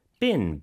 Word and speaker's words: been: Been, B-E-E-N. – 그렇죠. Been이 아니고 been. 이렇게 좀 짧게 발음하고요.